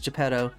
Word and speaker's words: Geppetto: as Geppetto.